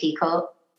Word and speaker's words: Picoult: And I think it's safe to say that the day that we got an email from best-selling author Jody Pico, Pico is the pronunciation, but it looks like Picoult, that changed our lives forever.